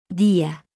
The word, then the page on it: Día: Spanish